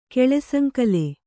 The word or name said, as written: ಕೆಳೆಸಂಕಲೆ